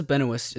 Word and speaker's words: Benoist: It's not Benoist. No, there's no T in that. Anyway, Melissa Benoist is the star of this show.